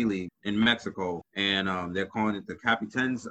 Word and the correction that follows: Capitanes: I, I hope that's how you pronounce it. – No, it's well, C- it's uh, the Capitanes. – Yes, it's Capitanes, and the team already existed.